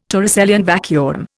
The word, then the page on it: Torricellian vacuum: Indian